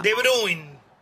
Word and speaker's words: De Bruyne: De Bruyne.